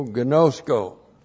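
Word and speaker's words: gnosko (γινώσκω): That's G-I-N, long O-S-K, long O, gnosko.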